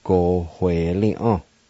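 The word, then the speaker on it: gowelia: Between the O and W, there's the H sound. Like this. Go we li ah. Go we li ah. Go we li ah.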